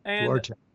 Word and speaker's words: Duarte: Napoleon Duarte? Duarte? I don't know if I'm saying that right. – And Duarte.